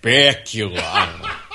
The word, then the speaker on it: Bakula: Bakula.